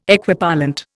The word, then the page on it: Equipollent: Indian